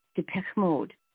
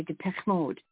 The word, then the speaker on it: Depeche Mode: It's Depeche Mode, okay? Depeche Mode.